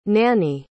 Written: nannie

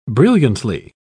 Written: brilliantly

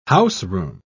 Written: houseroom

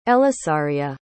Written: elisaria

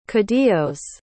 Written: caudillos

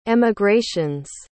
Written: emigrations

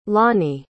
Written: lani

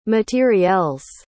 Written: materiels

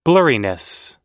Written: blurriness

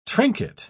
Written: trinket

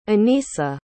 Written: Anissa

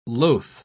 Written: loath

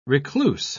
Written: recluse

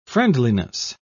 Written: friendliness

